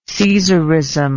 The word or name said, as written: caesaristic